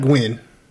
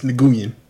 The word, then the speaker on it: Nguyen: It's not Gwen. It's Nguyen.